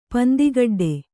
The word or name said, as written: ಪಂದಿಗಡ್ಡೆ